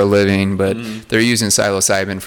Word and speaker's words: psilocybin: Like, there's very little way you pronounce it like that, because I think that's the proper way to say it too. – Psilocybin.